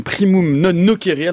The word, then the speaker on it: Primum Non Nocere: Et vous êtes créateur de la chaîne Primum Non Nocere. Alors je le dis en prononciation restituée, ça vous va ? – C'est-à-dire que c'est plus proche de la présentation originelle, effectivement.